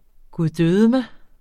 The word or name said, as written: guddødemig